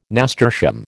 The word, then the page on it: nasturtium: American English